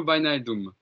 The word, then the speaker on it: Wijnaldum: C'est Jorginho Wijnaldum, le milieu de terrain hollandais qui a disputé l'Euro.